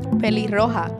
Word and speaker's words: pelirroja: Pelirroja.